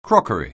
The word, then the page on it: crockery: anglais